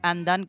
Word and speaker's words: Undun: Además, particularmente ese álbum que mega recontra recomiendo ir a escuchar, Andan, que se escribe Undun, digamos, mm.